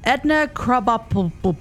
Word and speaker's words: Edna Krabappel: And finally, we have Edna Krabappel.